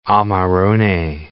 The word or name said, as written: amarone